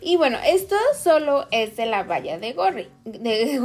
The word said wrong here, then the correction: Goji: Gorri, de Goji, es un poco difícil de pronunciar, pero bueno, es solamente del fruto lo que les estamos eh, contando.